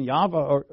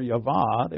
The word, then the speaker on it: Yahweh: They weren't calling him Jehovah, or no one called him Jehovah. That's a false pronunciation. But they weren't calling him Yahweh or Yavah.